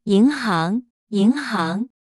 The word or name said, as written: 银行